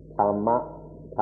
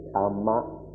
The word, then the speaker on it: Dhamma: Pali is the language of the earliest Buddhist scriptures, in which it's pronounced Tama, Tama.